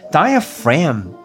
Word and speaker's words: diaphragm: 但是呢，我们把那个 G 的发音，把它变成 F 的发音，变成 diaphragm。